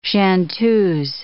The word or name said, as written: chanteuse